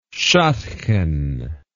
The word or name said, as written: shadchan